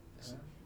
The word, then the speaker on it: Xi'an: How do you say it? Xian. – Xi'an.